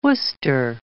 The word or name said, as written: Worcester